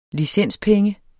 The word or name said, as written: licenspenge